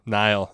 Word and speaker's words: Niall: Niall. That's how you say his name.